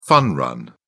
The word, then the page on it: fun run: British English